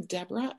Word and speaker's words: Debrah: My website is well first of all my name Debrah is spelled D E B R A H with no O.